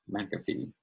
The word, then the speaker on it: McAfee: But technically, my channel name is Brett McAfee, spelled like the antivirus software.